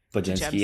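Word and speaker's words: Podziemski: Pod, what's the kid's name? Pazinski? – Yeah, did I say that right? Pajemski. – Pajemski, yeah, he's good.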